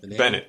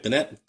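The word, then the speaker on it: Bennet: Is it Bonet? – Bennett. – Bennett. Bennett. That's how they pronounce it in France.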